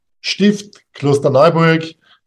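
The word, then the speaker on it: Stift Klosterneuburg: Stift Kloster Neuburg is the place that is waiting for you whenever you travel to Austria.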